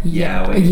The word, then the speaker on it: yaoi: Yaoi.